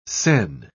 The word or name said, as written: sen